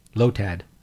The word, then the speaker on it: Lotad: First one. Lotad. – Glow-tad? – Lotad. – Lotad. How do you spell it? – L-O-T-A-D. – L-O-A? – L-O-T-A-D. It's only five letters, fucker.